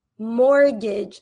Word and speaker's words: mortgage: This word is pronounced mortgage. Mortgage. So you can see that this T is actually silent. We do not pronounce the T in the word mortgage.